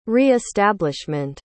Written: re-establishment

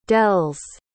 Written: dell's

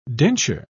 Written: denture

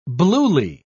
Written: bluely

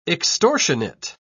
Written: extortionate

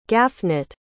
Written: Gafnit

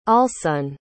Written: allsun

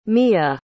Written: miya

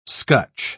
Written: scutch

